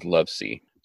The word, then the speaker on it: Lovece: And then I also used, so X marked the spot, and then I used The X-Files Declassified by Frank Lovesey, I think is how you say that. I found some different pronunciations for that name, so I'm going with Lovesey. So it's L-O-V-E-C-E.